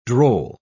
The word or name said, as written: droll